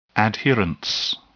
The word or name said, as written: adherence